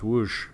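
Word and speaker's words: Łódź: For those who look at it and see LODZ, it's pronounced Woosh.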